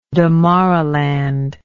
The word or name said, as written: Damaraland